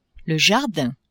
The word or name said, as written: jardin